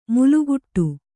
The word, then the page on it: ಮಲಗಟ್ಟು: malagaṭṭu